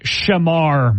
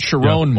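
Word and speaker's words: Shemar: The new one stars, let me pronounce this right, Shamar Moore. – Yes. – Not Sharon Moore.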